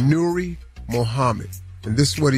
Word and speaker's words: Nuri: His name is Nuri Mohammed. N-U-R-I. Nuri Mohammed.